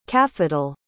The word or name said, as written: cafetal